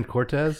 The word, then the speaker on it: Cortese: You sure it's not Dan Cortez from MTV? No. – That's him, but it's spelled Cortese, C-O-R-T-E-S-E.